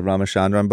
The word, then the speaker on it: Ramachandran: And I'm reading Phantoms in the Brain right now, the Ramachandran book.